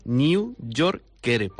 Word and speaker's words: New Yorker: El New Poyer. – Que es el New Yorker. Mira, te lo pronuncio despacio para que lo digas bien. New Yorker, porque viene de New York, de donde es la revista.